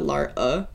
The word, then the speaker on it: Lara: Like, I would just hit me up through my username, Lara, LARUH. – Okay. – Which is also the phonetic pronunciation of my name, if that's helpful.